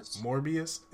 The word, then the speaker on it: Morbius: How do you say his name? Morbius? It is Morbius? – Yeah.